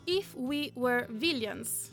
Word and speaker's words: If We Were Villains: Allora, oggi parliamo di un libro che si chiama If We Were Villains. – È giusta per la chi... pronuncia? Sì.